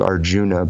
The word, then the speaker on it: Arjuna: The story of the Bhagavad Gita, where Arjun, is spelled Arjuna, but I think they usually pronounce it Arjun, is talking with Krishna.